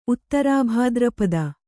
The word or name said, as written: ಉತ್ತರಭಾದ್ರಪದೆ